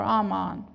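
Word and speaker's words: aman: Amen. Or Amon, if you pronounce it in Hebrew, but it's A-M-A-N.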